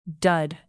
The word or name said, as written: dud